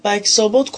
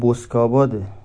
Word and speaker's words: بسک‌آباد: حالا اولا که بسک‌آباده و بکس‌آباد نیست، یعنی حالا تو اون حامد بسک‌آبادی، بکس‌آباد دیگه هیچ جوره خونده نمیشه.